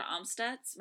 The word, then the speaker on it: Amstutz: You can find me on Twitter and Instagram and Snapchat and Letterboxd, all the other places, at Sandra Amstutz.